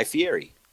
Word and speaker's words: Fieri: Said fee. Funny. – Yeah, free. – Like, Fieri. – Yeah. – You put some stank on it. You put some stank on it. – Yeah, you just gave it. But you put, you put it, as we say in Spanish, you put it. Pusiste el acento en el lugar incorrecto. Put the accent in the wrong place.